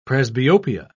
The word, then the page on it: presbyopia: British English